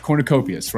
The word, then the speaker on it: Cornucopias: And I got the name right, Cornucopias, right? – Yeah, you did.